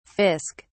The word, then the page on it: fisk: British English